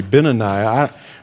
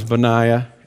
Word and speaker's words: Benaiah: And you're going, I don't even know if you're pronouncing that right. It might be Benaniah. No, it's Benaiah.